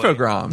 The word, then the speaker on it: Infogrames: I had been saying Infogrames like a phonetic dummy. I've seen it or heard it now pronounced Infogram, which is maybe because it's again a European publisher, so it could be a word from some language.